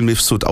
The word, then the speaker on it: Mifsud: Michael Mifsud, von dem ich aber gelernt habe mittlerweile, dass er Michael Mifsud ausgesprochen wird auf Malta, ist tatsächlich, ich habe jetzt nicht nachgeschaut, wie alt er ist, aber er muss gefühlt 58 sein.